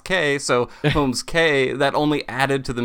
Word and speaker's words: Holmes Cay: How do you pronounce the name exactly? Cause we hear a lot of, is it Holmes Cay, Holmes Cay? – It's Holmes Cay. But part of that name was knowing that people would mispronounce it as Holmes Cay.